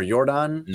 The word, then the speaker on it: Yordan: Is it Yordan or Yordan? – No, it's Yordan. – Yordan, I had it right the first time.